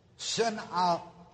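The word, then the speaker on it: synalizomenos (συναλιζόμενος): Now this word, synalizomenos, that I gave you, has two pronunciations. Either with a long A, then it would be pronounced synalizomenos. Synalizomenos. And when it is with a long A, it is translated assembled together with. Or it can be pronounced with a short A. Synalizomenos, syna, short A.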